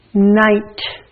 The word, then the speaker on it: night: GH makes the long I sound. Night. The IGH makes the I sound, so the GH is silent.